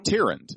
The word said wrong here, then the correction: Tyrande: It's Tyrande. – Tyrande? – Tyrande.